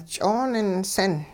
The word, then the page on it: ch'ooninsin: hupa